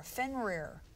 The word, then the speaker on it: Fenrir: His name is F-E-N-R-I-R, Fenrir. I don't know what that means. – Dirty game. – F-E-N-R-I-R, Fenrir. – Okay. Long I, short I.